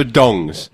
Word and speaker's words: Donges: Well, it's Christopher Dongs. – Does he? – I'm assuming that's how it's pronounced. D-O-N-G-E-S. I'm assuming it's not Donges.